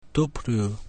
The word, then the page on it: W: latin